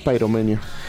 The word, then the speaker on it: Pyromania: Exactamente, porque esta canción que vamos a escuchar, que es Photograph, del disco Pyromania, ¿lo dije bien? – Pyromania.